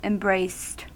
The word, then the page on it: embraced: American English